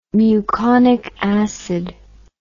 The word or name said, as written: muconic acid